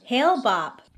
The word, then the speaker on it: Hale-Bopp: Around the same time, the group had learned about the approach of a comet named Hale-Bopp. Is that how you say it? I feel like that's not right. – Hale-Bopp.